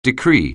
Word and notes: decree: anglais

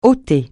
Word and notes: ôter: francais

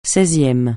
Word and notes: seizième: francais

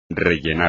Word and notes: rellenar: espagnol

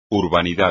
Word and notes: urbanidad: espagnol

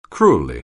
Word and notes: cruelly: anglais

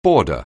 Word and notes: border: anglais

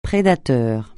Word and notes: prédateur: francais

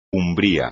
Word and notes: umbral: espagnol

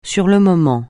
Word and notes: sur le moment: French

